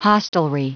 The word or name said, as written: hostelry